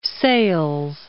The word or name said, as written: Francis of Sales